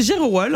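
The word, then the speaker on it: Gerewol: Nous, c'est Jerewol, en fait. – Jerewol. D'accord. – G-E-R-E. – Ça sonne Wolof. – Oui, ça fait ça sonne Wolof. – Jerewol. – Donc, le jour de la Jerewol, les hommes se mettent sur le 31, ils se maquillent, ils s'habillent bien et ils vont danser pour les femmes.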